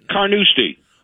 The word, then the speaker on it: Carnoustie: Carnoustie.